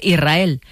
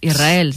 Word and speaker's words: Israel: Y la S con la R es difícil de pronunciar. La gente dice Israel, que suene la S. Israel, sí, es difícil de pronunciar.